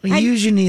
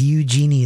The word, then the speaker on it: Eugenie: Eugenie is nice. Eugenie is not. – Not the way you were pronouncing it. – It's like Eugene.